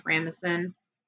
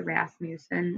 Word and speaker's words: Rasmussen: That's literally what they called him, was Big Ed, Sheriff Rasmussen. Sorry, Rasmussen. Let me pronounce things correctly.